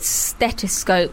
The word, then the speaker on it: stethoscope: The picture of you they've used on the Mail Online, and you've got your, I can't say it, stethoscope. – That's it, yeah, you said that right.